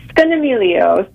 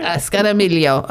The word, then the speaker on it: Scognamiglio: Scognamiglio, right? – Scognamiglio, yes. So yeah. I think the G is, you know, as an Italian, I could say I think the G is silent. Scognamiglio. – Scognamiglio.